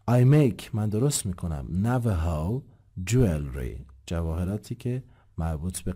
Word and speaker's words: Navajo: اینم که تصویرش اینجا هست، تلفظ میشه Navajo.